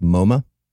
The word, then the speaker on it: MoMA: I think it's pronounced MoMA. – No, because it's like the mama, the mother of all museums. – Nope. – That's why not what MoMA means.